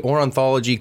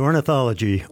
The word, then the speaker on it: ornithology: First, from the Natural History Museum of Los Angeles, he is the Ornithology Collections Manager. – That's me, and it's ornithology.